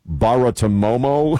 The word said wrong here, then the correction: Bartiromo: Romo, Baratomomo. – Bartiromo. – Bartiromo. I always have a problem with that.